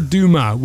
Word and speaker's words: Dumas: It's spelled D-U-M-A-S, Dumas. But I think you don't pronounce the S because it's French.